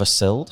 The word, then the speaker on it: Sild: So today I'm joined by Oliver Sild.